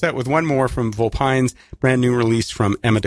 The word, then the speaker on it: Vulpynes: Because you said Vulpynes. – Yeah. – And I've been saying Vulpynes.